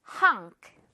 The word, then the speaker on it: honk: 자, 요거 그리고 호 발음 아니고 허 발음입니다. 입 크게 벌려주세요. 자, 듣고 따라합니다. honk. 이응 발음으로요. honk.